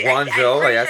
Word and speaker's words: Guangzhou: Guangzhou? – Okay, Guangzhou.